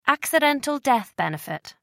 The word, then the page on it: accidental death benefit: American English